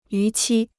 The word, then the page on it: 逾期: yú qī